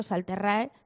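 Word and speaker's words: Sal Terrae: Forma parte de la colección Presencia Teológica del sello Salterre, que es la correcta pronunciación.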